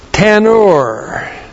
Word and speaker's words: tanur: Well, in verse 4, we have the word oven, and the word for oven in the Hebrew is tanur.